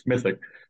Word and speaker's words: Smithwick: And you, if you're looking it up, like to buy the book, it's Smithwick, but I guess you don't pronounce the W as Smithick.